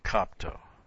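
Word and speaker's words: Kopto (κόπτω): K-O-P-T long O. Kopto.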